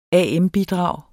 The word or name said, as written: am-bidrag